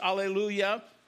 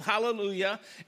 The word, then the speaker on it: hallelujah: You've heard that, or some have a silent H, alleluia. So the psalm begins and ends with hallelujah,